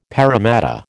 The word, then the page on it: Parramatta: American English